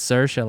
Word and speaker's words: Saoirse: The reason I know how to say her name is on talk shows, when Lady Bird came out, she'd say, "It's Saoirse, like inertia."